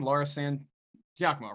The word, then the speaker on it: Laura San Giacomo: So Laura San Giacomo, is that say her name? Laura San Giacomo, right? – I think that's about right, yeah.